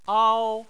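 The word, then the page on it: ao: tone 1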